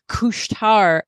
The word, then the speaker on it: Couche-Tard: And I actually got to see these firsthand when I visited the couche-tard, as it's pronounced in French, at the McGill lab when I was visiting Carl Boutet up in Montreal.